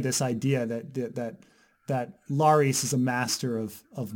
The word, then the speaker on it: Larys: His name is Larys. It rhymes with Varys.